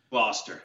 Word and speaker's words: Gloucester: Gloucester. – Gloucester.